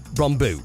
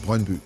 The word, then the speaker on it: Brøndby: Although I don't want to get the pronunciation right, uh, Peter, it's Brøndby, right? Brøndby.